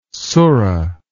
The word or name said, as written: surah